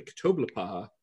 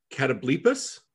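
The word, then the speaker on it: catoblepas: Actually, I'm pronouncing that wrong. I looked it up. It's apparently Catablepas, which is not how I thought that was going to be. I'm going to keep saying Ktoblepaha. Apparently it's like based on a Greek word, and so it's pronounced different, but I'm going to keep saying Ktoblepaha because that's how I'm used to it.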